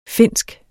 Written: Finsk

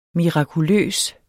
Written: mirakuløs

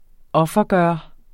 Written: offergøre

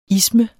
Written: isme

